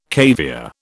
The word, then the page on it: caviare: British English